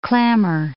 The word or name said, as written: clammer